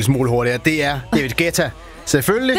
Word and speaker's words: Guetta: Dengang jeg arbejdede på Bruxelles Radio, der sendte han engang en fax om, at hans navn udtales Guetta. Og ikke Guetta, som alle os andre havde grund at kalde ham.